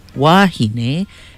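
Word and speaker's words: wāhine: Wahine, with the kahako or the makron over the A, pronounced wahine, is plural for women.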